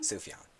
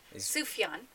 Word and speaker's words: Sufjan: Sufjan. – He could come and be on our podcast. Sufjan.